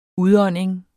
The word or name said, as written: udånding